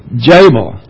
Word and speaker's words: Jabal: It's not Jubal, it's Jabal.